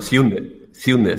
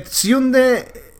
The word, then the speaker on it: Sjunde: Bueno, Sjunde, si no te importa. La J se pronuncia como I. – ¿Como I? Bueno, pues entonces... Sí. Det Sjunde Inseglet.